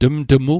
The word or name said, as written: tmtmu